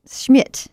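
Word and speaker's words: Schmitt: I'm Annie Laurie Gaylor, and we have in studio with us, which is always fun, our third staff attorney, Stephanie Schmidt. Did I say that right? Schmidt.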